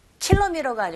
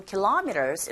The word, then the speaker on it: kilometers: 킬로미터가 아니고 kilometers 이렇게 발음하시고요.